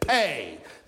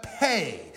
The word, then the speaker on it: Pei: And here in this section today, beginning in verse 129, we come to the 17th letter in that Hebrew alphabet, Pei. Now, it looks like P-E, but it's pronounced Pei.